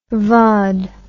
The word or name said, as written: vaude